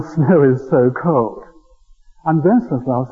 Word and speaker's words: Wenceslaus: I suppose if you're going to pronounce that correctly, it must be Wenceslaus.